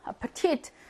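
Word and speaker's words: Appetit: 왜냐면, 강세가 여기 뒤에 있어. 보통 강세가 앞쪽에 있는데 얘 뒤에 있어서 자주 나오거든요. 앞에 티트. 강세가 여기로 갑니다. 근데 지금 우리는 이애 발음을 보는 거잖아요. 강세를 받지 못하는 애는 어떻게 된다고? 약하게. 약 모음 된다고. 그럼 얘처럼 하면 되겠네. 이렇게 발음합니다. 아파티트, 아파티트, 아페티트 이렇게 하지 않고요. 아파티트 강세가 뒤에 오니까 얘는 약해집니다.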